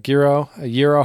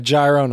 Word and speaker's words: gyro: That or a gyro, however you say it. A gyro, no, that's not how you say it.